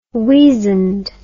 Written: weazand

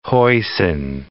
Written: hoisin sauce

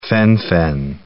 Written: phen-fen

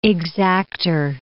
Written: exacter

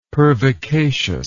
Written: pervicacious

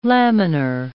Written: laminar

